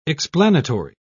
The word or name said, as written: explanatory